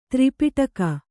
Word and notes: ತ್ರಿಪಿಟಕ: tri piṭaka

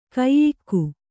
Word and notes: ಕೈಯಿಕ್ಕು: kaiyikku

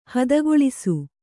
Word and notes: ಹೊದಕುಳಿಸು: hodakuḷisu

